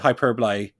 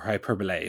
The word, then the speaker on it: hyperbole: For as much as they've used fucking Aaliyah and the Iconics, and Deonna Purrazzo couldn't get a turn, she's like, probably without hyperbole, or hyperbole, if I want to pronounce it right, better than any female wrestler on WWE's roster.